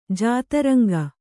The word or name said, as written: ಜಾತರಂಗ